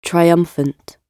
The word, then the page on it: triumphant: British English